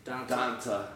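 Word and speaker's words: D'Onta: Is that how you pronounce it? – Donta. – It's the weirdest name. It looks so dumb when you type it in. Capital D apostrophe capital O N T A.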